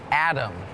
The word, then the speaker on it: atom: atom is tricky because it sounds like there's a d in it atom but it's actually a t o m but it's still pronounced atom